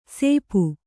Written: ಸೇಪು

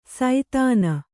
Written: ಸೈಧಾನ

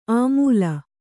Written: ಅಮೂಲ

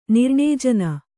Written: ನಿರ್ಣೇಜನ

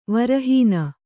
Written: ವರಹೀನ

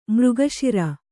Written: ಮಾರ್ಗಶಿರ

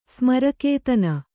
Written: ಸ್ಮರಕೇತನ

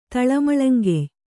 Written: ತಳಮಳಂಗೆಯ್

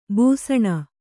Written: ಬೇಸಿನ್

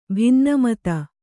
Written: ಭಿನ್ನಮತ

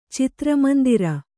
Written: ಚಿತ್ರಮಂದಿರ

